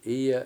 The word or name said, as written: Iye7